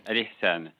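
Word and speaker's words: Ihsan: Alisan. Je ne sais pas si je prononce bien, c'est un mot arabe. – Tout à fait, vous prononcez très bien, Alisan.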